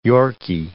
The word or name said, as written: Yorkie